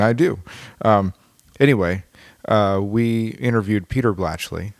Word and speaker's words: Blachly: The fact that I co-wrote this song with Clarence Dan Blachly.